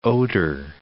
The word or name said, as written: Oder